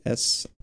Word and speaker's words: Intuos: I think it's Intuos. It's Intuos.